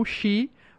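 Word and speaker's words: Shi (四): O número pode ser pronunciado como Shi, o que faz que tenha uma fonética idêntica da palavra japonesa que significa morte.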